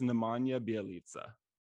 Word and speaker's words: Nemanja Bjelica: I believe it's Nemanja Bjelica.